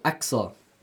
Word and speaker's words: Aqsah: A-Q-S-A-H. How would you pronounce that, bruv? – Akash. – Akash. – No, Aksa.